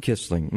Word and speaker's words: Quisling: pronounce it Quisling? – Quisling, mm-hmm. – And I've always thought it was Quisling. – Well, actually in America we say Quisling.